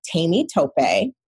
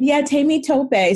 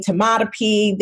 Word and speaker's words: Temitope: My real first name is Tami Tope. So it's spelled, Tami Tope. So T-E-M-I-T-O-P-E. But, you know, Americans fuck all kinds of names up. So they call me Tamatope. They call me Tamatope. They've called me Tomato.